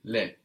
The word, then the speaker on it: les: it's plural, les, no? That's L-E-S. We can hear that S because the E has changed. Les, not le.